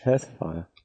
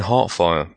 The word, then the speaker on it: Hearthfire: H-E-A-R-T-H. – Hearthfire. – I keep on saying Heartfire, but I want to say Earthfire.